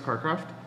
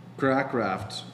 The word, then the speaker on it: Cracraft: I think it's pronounced Carcraft. – Nope. – And I thought he was a wide receiver. – Crackraft. C-R-A-C-R-A-F-T.